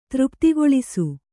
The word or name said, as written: ತೃಪ್ತಿಗೊಳಿಸು